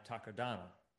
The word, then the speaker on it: Takodana: That same forest is used on Takodana.